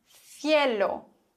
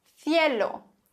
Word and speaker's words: cielo: Aber ein C vor E oder I wird gelispelt. Auf jeden Fall in Madrid und im klassischen Castellano. Das heißt, C vor E und I würde man aussprechen Cielo, Cielo, der Himmel.